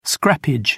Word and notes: scrappage: British English

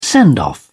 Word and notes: send off: British English

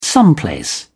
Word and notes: someplace: British English

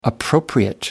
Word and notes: appropriate: American English

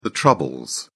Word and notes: troubles: British English

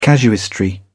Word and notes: casuistry: British English

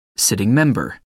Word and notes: sitting member: American English

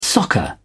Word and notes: soccer: British English

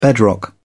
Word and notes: bedrock: British English

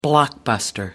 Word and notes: blockbuster: American English

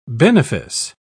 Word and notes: benefice: British English

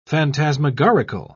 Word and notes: phantasmagorical: British English

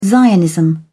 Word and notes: zionism: British English

